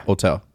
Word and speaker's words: Otell: Is that how you say it? Otell? – Yeah. – Otell. – Yeah. – Like hotel, but Otell. – Yeah, literally, yeah.